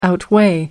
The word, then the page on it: outweigh: American English